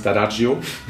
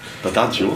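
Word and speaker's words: D'Addario: Ob das jetzt Ernie Ball ist, ob das Dadario ist, der Pro sagt übrigens Dadagio. Dadagio. Dadagio, genau. Können auch viele nicht aussprechen. Dann äh, Dadagio. Italiener, ganz klar.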